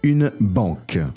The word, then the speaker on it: banque: Une banque.